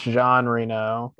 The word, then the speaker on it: Jean Reno: So, did I say that right, or is it Jean Reno? – I think it's Jean Reno.